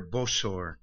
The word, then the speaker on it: Bosor: he's called here Bosor, Balaam the son of Bosor. So evidently that's how Peter was pronouncing it in the Aramaic that he spoke, and then it was transcribed in Greek. Some of your Greek Bibles have B-O-S-O-R. This one has B-E-O-R.